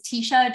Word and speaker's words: Teisha: So my name is Teisha Doriana, often mispronounced just because of how it's spelled.